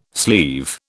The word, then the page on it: sleeve: British English